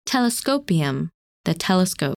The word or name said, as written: Telescopium